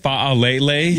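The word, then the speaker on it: Faalele: How about, how do you say it? Falele? – Yeah, that's, yeah.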